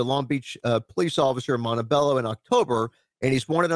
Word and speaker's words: Montebello: And is it called Montebello? Is that how you pronounce Montebello. – It? – Montebello. Yep. Montebello.